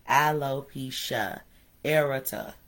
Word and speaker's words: alopecia areata: Alopecia areata.